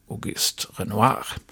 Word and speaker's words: Auguste Renoir: Museet det är tillägnat den impressionistiska konstnären Auguste Renoir.